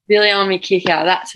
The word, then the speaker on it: Viliami Kikau: Viliami Kikau.